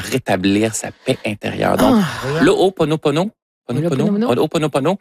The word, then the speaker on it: Ho'oponopono: Non, c'est une philosophie hawaïenne de, je vais le prononcer comme il faut, de Ho'oponopono. Ho'oponopono.